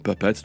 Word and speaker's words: Pipettes: Ça s'écrit pipette, hein, au pluriel, comme des pipettes en chimie, mais ça se prononce en anglais comme Puppets, donc poupée.